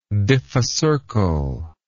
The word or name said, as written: diphycercal